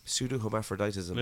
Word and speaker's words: pseudo-hermaphroditism: Okay, so pseudo-hermaphroditism. Pseudo-hermaphroditism. – Is that it? – Yeah. – Oh, that's the P. The P threw me off. – Tongue twister, I'm sure. – Pseudo-hermaphroditism.